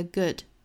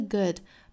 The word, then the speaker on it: good: nor a bad the good, nor a 辅元连读， nor a bad the good, bad the good, bad 尾部的也省掉了。那也要注意 good 的发音不要发成了 good，应该是 good， good，整个口腔肌肉是更松弛的状态，不是呜这样紧张。